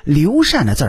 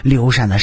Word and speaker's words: 刘禅: 那么刘禅的字儿是什么？公嗣。禅是与帝王有关的活动，而嗣是继承大业的意思。刘禅是要继承什么样的大业？帝王呗。所以刘禅的禅只能读成禅。